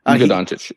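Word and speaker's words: Luka Dončić: Luka Doncic, yes.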